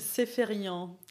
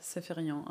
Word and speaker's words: Sepharion: Wie würdest du es aussprechen? – Sepharion. Oh, das ist Französisch, ne? – Ja, das ist eigentlich ein ursprünglich armenischer Name und über Armenien dann nach Libanon in den Frankreich gekommen. Mhm. Und ja, in Frankreich spricht man es halt Sepharion aus.